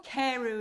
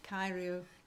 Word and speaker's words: Carew: Carew is how we say it in English. – Yeah, okay. In Gymraeg. – Carew. – So Castell Carew.